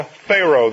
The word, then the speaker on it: Pharaoh: There's only one extra letter in the word Pharaoh. Paroah. Para-o. People usually misspell that. They spell it P-H-A-R-O-A-H, and of course it's not. It's Pharao. And there is a sound between the ah and the o, Pharao.